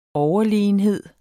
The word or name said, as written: overlegenhed